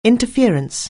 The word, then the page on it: interference: anglais